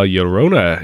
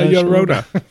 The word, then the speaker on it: Llorona: La la la la, Llorona. – He says the double L's are pronounced the same as Allende.